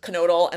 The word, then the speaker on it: Knoedl: Knoedl, and that is how you pronounce his last name. I like Googled it and everything.